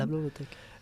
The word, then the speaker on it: La Blogothèque: La Blogothèque o algo así, no sé si lo pronuncia bien. – La Blogothèque.